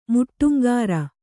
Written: ಮುಟ್ಟುಂಗಾರ

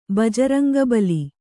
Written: ಬಜರಂಗಬಲಿ

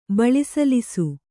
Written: ಬಳಿಸಲಿಸು

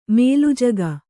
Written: ಮೇಲುಜಗ

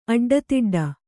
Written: ಅಡ್ಡದುಡ್ಡು

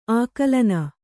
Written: ಆಕಲನೆ

